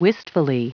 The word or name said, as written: wistfully